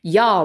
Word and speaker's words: Jah: Yah rides upon the clouds of majesty. I haven't thought about this in so long. But they spell it J-A-H.